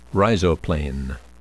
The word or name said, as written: rhizoplane